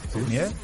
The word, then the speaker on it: Fournier: Fournier.